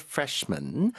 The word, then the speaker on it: freshmen: 발음상으로는 사실 구분이 안 됩니다. 여기서는 당연히 앞에 a lot of가 붙었기 때문에 복수형이고 a lot of freshmen ever since the new semester started.